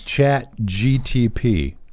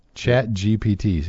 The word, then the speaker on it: ChatGPT: ChatGTP. GPT, rather. – Yeah. – ChatGPT.